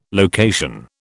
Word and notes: location: British English

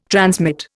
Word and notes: transmit: Indian

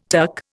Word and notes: tuck: Indian

